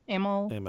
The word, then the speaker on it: Amell: I mean, Stephen Amell, is that how you pronounce his name? Stephen Amell? – Amell.